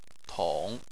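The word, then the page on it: tong: tone 3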